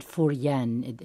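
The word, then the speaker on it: Fu Jen: And by the way, this was held, speaking of universities, this retreat was held at Furyen, is how it's pronounced, Furyen Catholic University.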